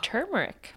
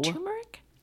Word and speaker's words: turmeric: Turmeric. – Turmeric. There's no R. – That's what I always thought, but I hear people say turmeric all the time, and I'm like, people say that wrong.